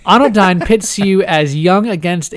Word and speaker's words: Anodyne: coming out on the 28th is called Anodyne. Did I say that right? – Uh, Anodyne. Anodyne. Yeah, yeah. Anodyne.